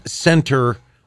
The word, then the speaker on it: Centre: C-E-N-T-R-E. – No, it's Center. – I know, but I don't want to say Center because people will think it's C-E-N-T-E-R. So I'm specifically trying to be like over phonetic with the T-R-E so people get that it's Sentry.